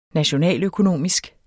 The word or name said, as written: nationaløkonomisk